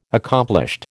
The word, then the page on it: accomplished: American English